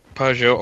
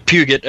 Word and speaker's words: Peugeot: Well, I wouldn't know, because I've never seen the Peugeot, or the Puget, as we like to say over here, because we like to pronounce things as we see them.